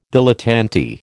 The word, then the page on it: dilettante: American English